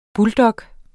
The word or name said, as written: buldog